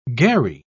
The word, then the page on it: gharri: British English